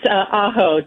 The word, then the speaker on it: Aho: It's Aho, Deborah Aho Williamson.